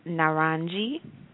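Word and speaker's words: Naranji (नारंगी): A-N-I, bagani. The fourth color is orange, and that is pronounced Naranji, and that is spelled N as in Nathan, A-R-A-N-G-I. And the last color is blue, which is pronounced Nila, and is spelled N-I-L-A.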